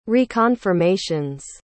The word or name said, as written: reconfirmations